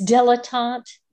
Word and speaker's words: dilettante: It's dilettante. – Oh, thank you. Dilettante.